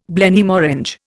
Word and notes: Blenheim Orange: Indian

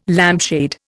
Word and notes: lampshade: Indian

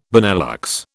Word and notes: Benelux: British English

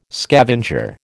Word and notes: scavenger: American English

